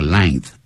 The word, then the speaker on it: length: 那是 at, 再加一个 length, L-E-N-G-T-H.很多人不会念这个字，念成 length，叫 length， length， at length，详细的把情形告诉我。好，我们接着继续看。